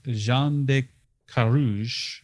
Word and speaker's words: Jean de Carrouge: King Charles VI declares that Knight Jean de Carrouge, mm-hmm.